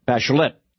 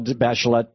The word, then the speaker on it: Bachelet: By the way, Michel Bachelet in Chile, they pronounce the T. Michel Bachelet